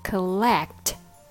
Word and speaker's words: collect: Now, how can you pronounce collect correctly? collect starts with col. Same thing applies with the letter O. Don't say call, say cull. No letter O sound, but letter U sound instead.